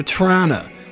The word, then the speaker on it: Toronto: I call it Toronto sometimes, and my friend Allison goes, dude, we from Toronto call it Toronto or Trana. When you pronounce the T, that's whack.